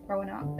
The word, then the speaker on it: Roanoke: Also, did you know that Lady Gaga was in the series before Rannoch? – Yeah, she was like the witchy demon. – Yeah. – Roanoke. – Roanoke, there we go. You always say it wrong.